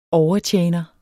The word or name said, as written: overtjener